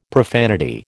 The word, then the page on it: profanity: American English